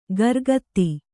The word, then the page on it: ಖುರಗತಿ: khuragati